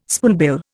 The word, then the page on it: spoonbill: Indian